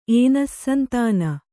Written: ಏನಸ್ಸಂತಾನ

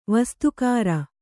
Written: ವಸ್ತುಕಾರ

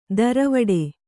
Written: ದರವಡೆ

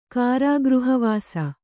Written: ಕಾರಾಗೃಹವಾಸ

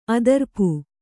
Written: ಅದಿರ್ಪು

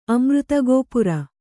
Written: ಅಮೃತಗೋಪುರ

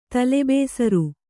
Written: ತಲೆಬೇಸರ

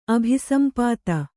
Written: ಅಭಿಸಂಪಾತ

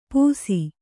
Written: ಪುಸಿ

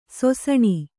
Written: ಸೊಸಣಿ

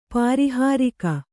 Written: ಪಾರಿಹಾರಿಕ

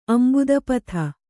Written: ಅಂಭೋಧಿಪಥ

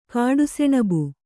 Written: ಕಾಡುಸೆಣಬು

